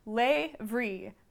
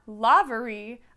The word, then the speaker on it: Lavery: It says, by the way, it's pronounced Lavery, not Lavery, a soft A in the middle, similar to have, not gave.